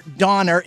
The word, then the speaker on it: Donner: It's Dasher, Dancer, and Donder or Donner.